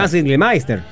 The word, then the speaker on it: Hans Gildemeister: Hans Gildemeister.